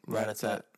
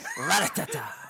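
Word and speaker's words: Rattata: Ratatat. – It's a Ratata. – We said pretty much said the same thing. – It's you put a little emphasis on the at the end.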